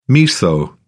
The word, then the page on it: miso: British English